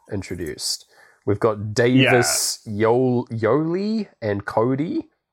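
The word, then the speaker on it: Yolei: It said Yoli. She pronounced her name to rhyme with Go See.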